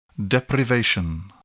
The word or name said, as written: deprivation